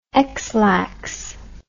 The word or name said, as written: ex lax